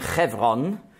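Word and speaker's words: Chevron (חברון): Hebräisch ausgesprochen Chevron.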